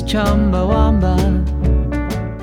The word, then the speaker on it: Chumbawamba: Listen to how they pronounce it in this clip. Not Wamba, but Wamba. – It's Chumbawamba.